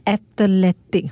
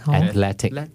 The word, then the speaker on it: athletic: 不是 athletic. – Athletic. – Athletic. So three syllables only. Not athletic. – He's very athletic.